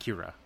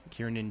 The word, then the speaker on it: Kiernan: Kiernan, right? – Yeah. – Not Kira. Kiernan Shipka.